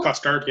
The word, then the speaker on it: Costard: So I believe I'm pronouncing this character correctly, but Costard, our fool. – Costard, yeah.